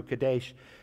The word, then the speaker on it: Kadesh: That's how you say it in Hebrew, Kadesh.